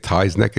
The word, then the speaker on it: Tyznik: I'm pronouncing it Tyznik. It's T-Y-Z-N-I-K. And he's from Holy Coke, H-O-L-Y-O-K-E, Holy Coke, Holy Coke, Colorado.